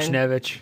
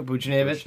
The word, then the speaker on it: Buchnevich: Buchnevich. – Thank you. Buchnevich.